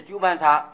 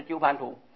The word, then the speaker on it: 鸠盘茶: 那么日本书里面所有鸠盘茶都写的苦伴茶，所以可见这个茶是大，不是土啊，毕竟是鸠盘茶，不是鸠盘土。假如有印作土字的，也要念作茶，不要念成鸠盘茶、鸠盘土啊。